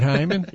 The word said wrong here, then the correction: Heyman: It's Heyman. I think it's actually pronounced Heyman.